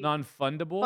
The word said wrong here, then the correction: fungible: Fungible. Fungible. – Non-fungible. For sure. – I assume that's how you say it.